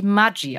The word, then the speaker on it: Maggi: aber mit norditalienischen Eltern und eigentlich Maggi ausgesprochen wird.